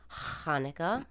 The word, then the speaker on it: Hanukkah: Everybody who listens to the show knows that Michael is Jewish, and so he celebrates Hanukkah. Did I say that right? – Yeah.